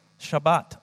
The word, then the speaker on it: Shabbat: Shabbat, I think, is the correct way to pronounce it in the original language.